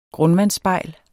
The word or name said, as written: grundvandsspejl